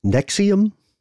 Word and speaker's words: NXIVM: NXIVM? – That's it.